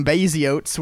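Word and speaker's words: Baziotes: Adolf Gottlieb and William Baziotz were communist activists. That, I refuse to be corrected. That is how you say his name now.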